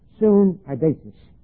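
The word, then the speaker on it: syneidesis: The Greek word, soon, adesis, I gave it to you before, but it's S-U-N, the prefix soon, which means with or co, and idesis, E-I-D-E-S-I-S. It's one word, but it's soon, idesis, long E. S-U-N-E-I, pronounced as I, D-E, pronounced as A, day, sis, S-I-S.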